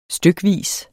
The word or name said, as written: stykvis